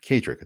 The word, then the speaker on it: Kaedrich: Just look for my name, Kaedrich. It's spelled K A E D R I C H and you'll unlock a whole world around you.